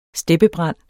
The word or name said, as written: steppebrand